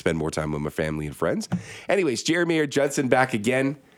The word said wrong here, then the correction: Geri Mayer-Judson: Ooh. I say that right? Jerry Mayer Judson. – Jerry Mayer Judson. I know, triple barrel name. It's easy to mess up.